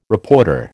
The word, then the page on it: reporter: American English